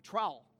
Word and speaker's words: trowel: He came back with a shawl. Is that what it's called? A shawl? How do you say that? A trowel. Thank you, shawl.